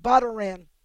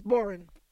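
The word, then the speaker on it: bodhrán: if you pronounced it the way it's spelled, it looks like bodoran. It's pronounced boran.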